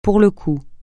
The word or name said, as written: pour le coup